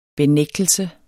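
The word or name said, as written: benægtelse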